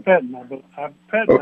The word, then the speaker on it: Pettine: How do you pronounce the Green Bay defensive coordinator's name? Is it Mike Pettine? – Yeah, Mike Pettine. – Pettine? – Pettine, I believe is correct.